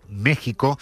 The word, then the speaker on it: México: Se escribe con X, pero se pronuncia con J, ese país que no existió hasta que llegaron los españoles, según Ayuso. – Mire, México no existió hasta que llegaron los españoles.